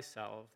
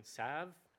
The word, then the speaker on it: salve: By the way, I've heard the pronunciation salve, I've heard the pronunciation salve, and I'm going to go by the British pronunciation because it's also the root word of salvation, and salve are the same thing, just for consistency if anybody's not happy with my pronunciation here.